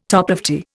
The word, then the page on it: toplofty: Indian